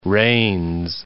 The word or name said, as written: Reines